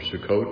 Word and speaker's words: Sukkot: It depends on what tradition you are, Sukkot or Sukkot. It depends on if you're Ashkenazi or Sephardic on how you pronounce it. I call it Sukkot, but some say Sukkot.